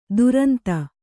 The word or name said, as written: ತುರಂತ